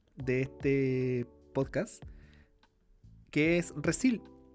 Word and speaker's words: Resille: Resille, así se escribe como como los nombres de Michelle, Giselle, bueno, Resil, se escribe Resille.